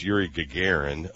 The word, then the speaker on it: Yuri Gagarin: It was Yuri Gagarin, and it was 3 weeks, not days, before Alan Shepard's flight.